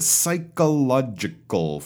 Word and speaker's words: psychological: First of all, I don't think I heard all five syllables. Psychological. That's definitely five syllables. So make sure you got the right number of syllab- syllables because if you don't, you got a problem already.